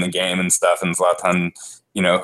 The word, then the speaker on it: Zlatan: been trying to do better with I believe Zlatan is is the better pronunciation.